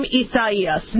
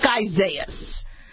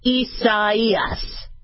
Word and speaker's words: Isaias: Now, it looks like Isaias because in America, the AI is one sound, but this, the name wasn't chosen to be American. This was a Hispanic, Portuguese name.